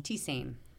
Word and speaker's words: tisane: How do you pronounce the word T-I-S-A-N-E? – T-Sane. – T-Sane. Okay, good.